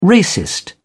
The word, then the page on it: racist: British English